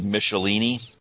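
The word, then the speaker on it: Michelinie: I believe it's David Michelini, as opposed to pronounced like the tire company, though.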